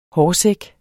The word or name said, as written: hårsæk